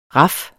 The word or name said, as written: raf